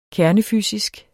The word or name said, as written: kernefysisk